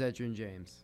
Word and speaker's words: Edgerrin James: I think I pronounced that name wrong. – Let me see. – What's his name? – I pronounced it wrong. You're our resident name pronouncer corrector. – It is Edgerrin James. – It is Edgerrin James. – Okay, I don't even know who that is. – I was right.